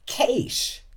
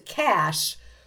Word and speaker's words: cache: This fascinated me, looking up pronunciation of cache, C-A-C-H-E. And we found in Australia, it was common to hear it as Keish. – Keish. – Yeah. Because this one person in a language forum said, an American friend of mine pronounces it cash. And that just sounds so odd.